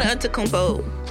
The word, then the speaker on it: Antetokounmpo: Yeah, oh, wait, what's the one Antetokounmpo play for? – Yeah, the Milwaukee Bucks.